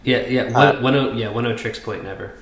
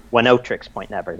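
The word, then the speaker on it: Oneohtrix Point Never: Oh, No Tricks Point Never. – One Tricks Point Never. – Mm-hmm. One Tricks Point Never.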